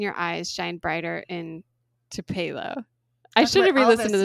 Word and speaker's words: Tupelo: Like, to Pilo? How do you say it? To Pilo? – Tupelo? Tupelo? – To Pilo.